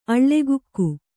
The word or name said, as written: ಅಳ್ಳೆಗುಕ್ಕು